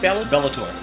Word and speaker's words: Bellator: Bellator.